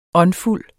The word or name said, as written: åndfuld